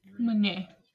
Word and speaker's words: Meunier: So the main grapes that are used for champagne are Chardonnay, Pinot Noir, and Meunier, I think is how you say it. – Meunier.